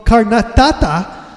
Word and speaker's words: Karnataka: Yes, that is exactly what is going on in the tiny village of Karnataka.